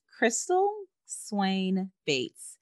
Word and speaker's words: Crystal Swain Bates: All of my books are on Amazon and my name, it's not quite the easiest to spell, but it is Crystal Swain Bates. And I'll spell it for you. It's Crystal, C-R-Y-S-T-A-L.